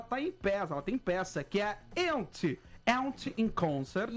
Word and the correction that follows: Yentl: Yentl. – Yentl. – Perdão, eu vou fazer com pronúncia. Yentl in Concert.